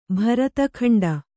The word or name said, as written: ಭರತಖಂಡ